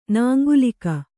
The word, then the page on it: ನಾಂಗುಲೀಕ: nāŋgulīka